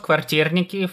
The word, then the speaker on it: kvartirniki: Yeah, so they're called kvartirniki, from the word kvartira, which is apartment.